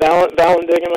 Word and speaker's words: Vallandigham: How do you say his name? Vallandigham? – Yeah, Clement Vallandigham, I think, yeah.